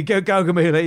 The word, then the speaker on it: Gaugamela: Yeah, Gaugamela, yeah.